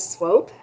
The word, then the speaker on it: Swope: First of all, a clarification, my last name is Swope. It just rhymes with rope.